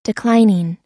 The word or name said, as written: declining